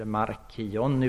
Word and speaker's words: Marcion: Han heter Marcion, M-A-R-C-I-O-N, eller Markion uttalas han ibland, jag vet inte vilket.